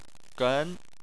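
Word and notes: gen: tone 2

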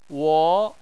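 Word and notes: wo: wo2; tone 2

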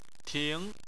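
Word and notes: ting: tone 2

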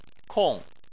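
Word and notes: kong: tone 4

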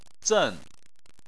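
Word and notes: zen: tone 4

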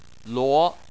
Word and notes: luo: 2

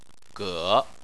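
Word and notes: ge: tone 3

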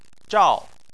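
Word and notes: zhao: tone 4

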